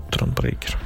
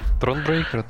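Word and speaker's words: Thronebreaker: А, что касается вот следующей игры, это Тронбрейкер, э, который... Я правильно говорю? Тронбрейкер. Тронбрейкер, да.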